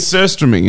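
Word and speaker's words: surströmming: How do you say it? – It's surstromming, isn't it? – Yeah.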